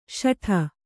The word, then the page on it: ಶಠ: śaṭha